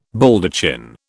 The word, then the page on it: Baldachin: British English